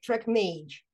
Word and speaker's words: TrackMage: Because even native speakers would struggle with pronouncing the name, like Track Mage.